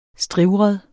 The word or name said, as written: strivret